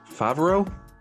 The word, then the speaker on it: Favero: So, Matt details Favero.